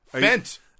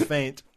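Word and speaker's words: feint: Fent. – Nope. That's faint. It's not an accent. That's just mispronunciation.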